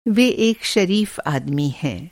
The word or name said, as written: शरीफ़